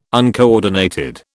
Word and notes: uncoordinated: British English

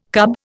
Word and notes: cub: Indian

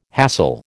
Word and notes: hassle: American English

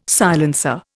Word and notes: silencer: Indian

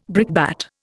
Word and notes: brickbat: Indian

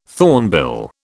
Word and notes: thornbill: British English

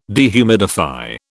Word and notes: dehumidify: British English

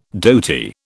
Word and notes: dhoti: British English

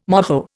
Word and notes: moho: Indian

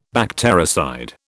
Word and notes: bactericide: British English

